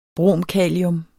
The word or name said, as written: bromkalium